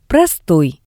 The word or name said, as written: prostoi